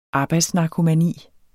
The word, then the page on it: arbejdsnarkomani: ˈɑːbɑjds-